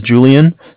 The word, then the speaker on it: Julian: My name is Julian. It's J-U-L-I-A-N. – Okay. – And I'm calling from Bank of Cardiff. It's spelled C-A-R, D as in dog, I-F-F.